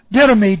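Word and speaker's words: Didomi (δίδωμι): Didomi. D-I-D long O-M-I.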